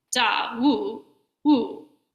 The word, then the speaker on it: Dawu: So I took my Jukai there in 2013 and went through this lay ordination ceremony and got my name, which is Dawu. I don't say it properly. It's a Chinese Dharma name, so it's more like Da Wu Wu. It goes down on the second tone.